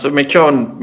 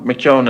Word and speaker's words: McEwen: Is it Patrick McEwen or McEwen? – Yeah, that sounds McEwen. McEwen is how it's pronounced.